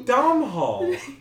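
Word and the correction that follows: Domhnall: No, it's Donal. The Donal? – It's like Donal or.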